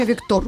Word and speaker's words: Виктор: За границей это имя Виктор. Ударение другое, сразу по-другому слушается.